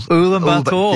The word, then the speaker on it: Ulaanbaatar: Ulaanbaatar.